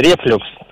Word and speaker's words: рефлюкс: Согласно данным русских орфографических словарей, в данном слове ударение всегда ставится только на второй слог. Поэтому правильно говорить рефлюкс, уважаемый Антон.